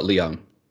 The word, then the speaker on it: Leung: Leung.